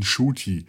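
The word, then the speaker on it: Ncuti: es ist ein Shuti. Das N spricht man kaum aus und da Shuti Gatwa das bis zu seinem 26. selber falsch gemacht hat, ist es in Ordnung, Shuti zu sagen.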